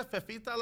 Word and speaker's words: Fefita: Fetita, ¿cómo se dice? Fefita la feta.